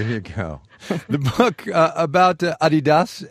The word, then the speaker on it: Adidas: You've been saying Adidas, as in Adi Dassler, Adidas. Over here we say Adidas. Which one's right? – Oh, definitely the European pronunciation, and it's really strange. I really can't explain why you pronounce it differently in the US.